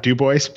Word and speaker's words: Dubois: Have I said your surname right, Scott, even? – It's actually Scott Dubois, but that's quite all right, Andy.